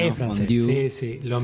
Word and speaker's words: fondue: Hay que poner la boca para decir U, pero decir I. – Okay. – Entonces, una sola vocal al final, fondue. Pones para U y decís y pronuncias la I.